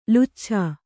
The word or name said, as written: ಲುಚ್ಛಾ